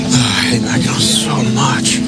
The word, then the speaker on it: Mackinac: When he goes to Mackinac Island? – Oh, no, Mackinac Island.